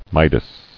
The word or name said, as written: Midas